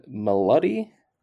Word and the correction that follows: Milioti: Kristen Milioti.